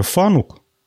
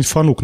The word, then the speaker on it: Fanuc: Японская компания Fanuc, оказывается, с ударением на второй слог надо произносить, я ее всю жизнь Fanuc называл она строит инжиниринговый центр в Сколково.